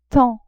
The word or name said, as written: tant